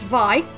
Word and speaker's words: Vay: So next up is, it's spelt Vay, V-A-Y, but it's actually pronounced Vi, not to be confused with Steve Vai, the Whitesnake guitarist.